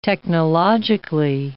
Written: technologically

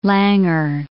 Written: Langer

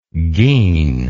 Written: gean

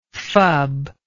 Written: fub

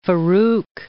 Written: Farouk I

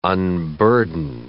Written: unburden

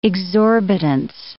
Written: exorbitance

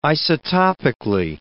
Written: isotopically

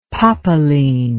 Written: popeline

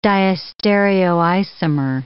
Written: diastereoisomer

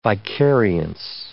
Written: vicariance

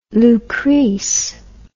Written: Lucrece

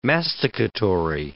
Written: masticatory

